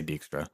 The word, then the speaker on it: Dijkstra: I hear it get pronounced a couple of different ways sometimes. Okay. I always say Dijkstra. I feel like that's what it's how they say it in the games, and that was my first exposure to him as a character.